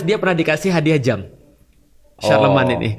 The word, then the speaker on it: Charlemagne: Oh, Charlemagne bacanya apa? – Charlemagne.